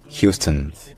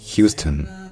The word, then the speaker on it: Houston: Effectivement, en français, on est tenté de lire ça Houston parce que c'est littéralement comme ça que ça se lirait, selon l'orthographe française. Mais en anglais, c'est Houston. Houston.